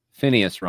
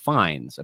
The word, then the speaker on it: Fiennes: Well, I pronounced Ralph Fiennes wrong because it's actually Ralph Fiennes, apparently.